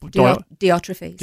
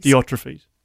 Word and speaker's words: Diotrephes: Diotrephes. – Diotrephes.